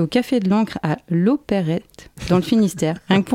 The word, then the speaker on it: Loperhet: Ah d'accord, donc ça se prononce bien comme ça, l'Opérette. – L'Opérette. – Et ça s'écrit L-O-P-E-R-H-E-T. – Ouais, tout à fait, ouais.